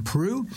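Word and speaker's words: Proulx: John Prue. Now, just tell you how he spells his name. It's P-R-O-U-L-X, but I'm assured he pronounces it John Prue.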